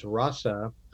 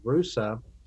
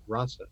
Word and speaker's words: Russa: And one note on that is I'm going back to referring his eh, pronouncing his middle name as Russa. I did that originally and was corrected by a number of people who said it was Rusa. But his great grandson has told me that the family has always pronounced it Russa.